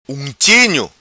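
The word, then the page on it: Unchinho: Macanese Patuá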